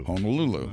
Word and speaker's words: Honolulu: Honolulu. That's Honolulu. How they pronounce it. That's the Hawaiian Honolulu. Way of saying it.